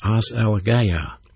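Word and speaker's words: as elegeia (ἀσέλγεια): To pronounce the word is as elegeia.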